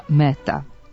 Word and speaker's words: Mehta: Na hindi jeziku, koji je jedan od službenih u Indiji, odakle je Mehta rodom, njegovo ime zaista se izgovara Mehta, a pisani oblik preuzeli smo iz engleskog.